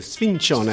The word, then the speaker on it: sfincione: Bien prononcé, sfincione.